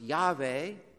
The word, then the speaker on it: Yahweh: And he said, say this to the people of Israel, I am, or as it was probably pronounced, Yahweh. Yahweh has sent me to you.